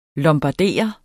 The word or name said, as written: lombardere